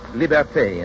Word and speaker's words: liberté: Liberty is pronounced liberté in Haiti and Martinique, libertad in the islands that still keep the Spanish tongue.